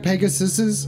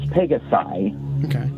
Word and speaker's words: Pegasi: Real quick, I got a question about just how you pronounce Pegasus. Isn't it Pegasuses? Pegasuses? Are you sure it's Pegasi? – It is Pegasi.